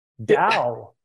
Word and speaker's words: Tao: Well, first off, it's pronounced with a D, but it's spelled with a T. – I don't know why. – Dao.